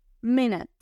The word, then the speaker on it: minute: Pero la segunda parte nute, lo que ocurre aquí es uno, la u suena como a y la e es muda, entonces minute. Minute.